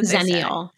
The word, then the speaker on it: Xennial: That's a Xennial.